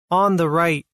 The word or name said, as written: On the right